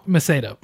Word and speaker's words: Macedo: Pronounce her last name, Macedo? – Macedo.